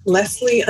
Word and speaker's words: Lesleigh: So I am on Twitter at Leslie underscore Ford, and my name is spelled L E S L E I G H underscore Ford.